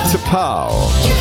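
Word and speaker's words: T'Pau: Wie spricht man diese Band aus? T-Apostroph-Pau. T-Pau. Eigentlich ganz einfach.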